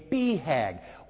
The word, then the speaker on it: BHAG: And that's how you pronounce it, a BHAG.